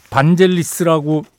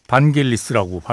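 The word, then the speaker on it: Vangelis: 보통 우리가 반젤리스라고 많이 얘기하는데 어, 그리스에서는 반겔리스라고 발음이 된답니다. 예. 저도 뭐 야니라고 왜 그리스 출신의 뮤지션 있잖아요. 야니가 출연했을 때 반겔리스하고 뭐 친구라고 뭐 얘기하면서 반겔리스라고 발음하더라고요.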